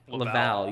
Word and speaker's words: Laval: So Buffalo, UCI, Laval. I think that's how it's pronounced. – Laval.